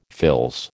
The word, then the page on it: fils: American English